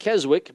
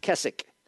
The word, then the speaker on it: Keswick: In England, these camp meetings drew into the later annual, now that looks like Keswick, but it's pronounced Keswick, into the annual Keswick conventions for seeking a higher life with Christ.